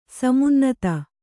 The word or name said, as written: ಸಮುನ್ನತ